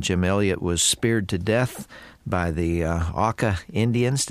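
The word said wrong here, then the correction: Auca: First syllable rhymes with cow. – Ah. – Auka.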